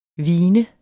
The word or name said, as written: hvin